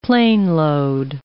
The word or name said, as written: planeload